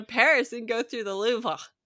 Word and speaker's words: Louvre: That's how you say it, right? The Louvre. – The Louvre. Louvre. Yeah, it's Louvre. Get your phlegm out.